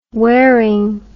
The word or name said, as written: waring